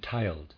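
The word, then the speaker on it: tyled: "Has the lodge been tiled?" Readers aside, spelled T-Y-L-E-D. I am pronouncing that tiled.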